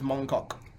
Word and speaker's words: Mong Kok: I will tell you exactly how to pronounce that. That is Mong Kok.